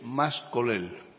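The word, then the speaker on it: Mas-Colell: Insiste mucho, perdona, Luis, el señor Colau, es más colel.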